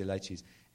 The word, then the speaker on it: lychees: My food hell was, how do you say it in America, lychees, is that what you say? Lychees, those little fruit, those little, lychees, yes, we say lychees.